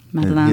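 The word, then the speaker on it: madeleine: Is that how you pronounce that? Madeline? – Madeline.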